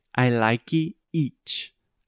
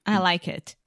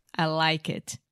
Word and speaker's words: I like it: If you have issues with these two aspects of English pronunciation, you will say, I like each. I like each. – I like it. I like it. – Yeah. I hear I like each so many times from Brazilians, and I'm thinking, I understand that because I've heard the mistake millions of times, but the average native speaker will literally think you're speaking a different language.